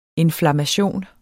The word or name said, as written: INFLAMMATIONS